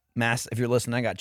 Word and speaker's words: Mads: No, Mads Mikkelsen. Mads. – Why do you keep saying mass? – This is how it's pronounced. – His name is pronounced mass? – Mm-hmm. – Is it not like M-A-D-S? – That's how it's spelled.